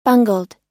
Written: bungled

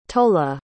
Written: tolla

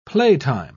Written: playtime

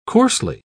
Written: coarsely

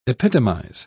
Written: epitomize

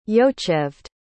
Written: yocheved

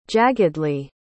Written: jaggedly

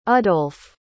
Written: udolph

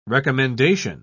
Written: recommendation

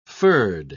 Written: furred

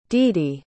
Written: didi

